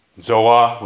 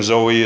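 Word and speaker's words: Zoa (ζωή): Zoa would be the right pronunciation. Zoe is how we kind of cutely go with it.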